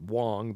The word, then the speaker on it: Wang: I actually pronounced it Wong, but it's W-A-N-G.